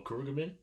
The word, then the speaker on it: Khruangbin: Uh, how how do you say like Khruangbin? – Oh, Khruangbin. Yes. Yeah.